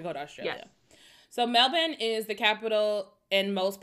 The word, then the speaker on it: Melbourne: So first, this all takes place in Melbourne. Not Melbourne, Melbourne. That's how they say it in Australia.